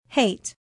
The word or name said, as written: hate